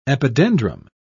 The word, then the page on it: epidendrum: British English